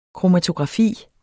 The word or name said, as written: kromatografi